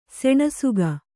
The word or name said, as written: ಸೆಣಸಿಕೆ